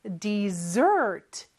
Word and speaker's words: dessert: 그리고 스펠링 잘 보세요. S가 두개 있습니다. Dessert 이렇게 뒤에 강세가 있고요. 같이 한번 따라해 볼까요? Dessert. Dessert. 네, 그리고 S 발음이 Z 발음이네요.